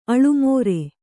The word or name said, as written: ಆಲಮರು